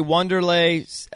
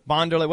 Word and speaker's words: Wanderlei: I think they say Wanderlei Silva. Is that how you say his name? – I think it's Wanderlei, whatever it is.